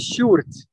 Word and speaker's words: Sjoerd: My name is Sjoerd.